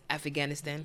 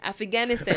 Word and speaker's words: Afghanistan: Or as my mother says, Afghanistan. – Oh, boy. – So it has enough syllables. You didn't have to add one. Afghanistan.